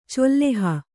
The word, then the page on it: ಚೆಲ್ಲೆಹ: celleha